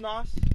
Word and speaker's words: Nos: His new name is N-O-S. – Sorry, Nos. – I should have Googled that. – Pronounced as Nos? – Pronounced as Nos. – Like nitrous? – I like what we're talking to him.